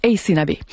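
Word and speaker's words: Aysanabee: Alors, je vous appelle, son nom, c'est A-Y-S-A-N-A-B. Ça se prononce Ace Nabé.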